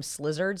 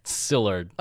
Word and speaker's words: Szilard: When I don't know how you say his name, but I kept calling him Slizzard, so I'm going to keep calling him Slizzard. – Oh, yeah. It was S-Z-I-L-A-R-D. – Slizzard? – Sillard.